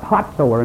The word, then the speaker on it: Hazar: Hazar Enan, that's pronounced Hotzor in Israel.